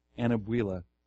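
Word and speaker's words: Anyabwile: Okay, how do you pronounce it? Anabuela. Yeah, Thabiti Anabuela.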